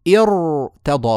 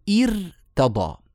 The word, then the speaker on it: ارتضى: لما نبدأ بالكسر هذه الهمزة همزة الوصل هي أصلا جئنا بها حتى حتى نتوصل بها إلى النطق بالساكن اسمها همزة وصل يعني هي أصلا همزة عارضة فكسرتها عارضة مثلها كسرتها عارضة مثلها كذلك فلذلك لا تكون هذه الكسرة مسببة للترقيق كويس فنقول ارتضى ولا نقول ارتضى